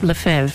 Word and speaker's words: Lefebvre: Archbishop Lefebvre. Thank you. I've said it before. I had it in my mind, but, you know, when you're on the spot and I'm reading it, I'm going, Lefebvre? Archbishop Lefebvre.